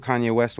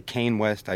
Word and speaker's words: Kanye: and I had no idea who Kanye West was. I used to say Kane West. I used to pronounce it right.